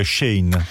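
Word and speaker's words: Shein: Però visto che prima hai parlato di, tu hai detto Shine, ma è un po' un mistero come pronunciare il nome di questa azienda, perché in realtà è un'azienda cinese, quindi alcuni dicono Shane, alcuni dicono Shane. – Forse Shane, ho sbagliato io, Shane.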